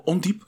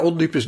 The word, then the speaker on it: Ondiep: En je bent opgegroeid, kan nog één keer uh, ondiep, zeg ik het goed? – Ja, ondiep is in Utrecht.